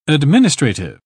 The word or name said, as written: administrative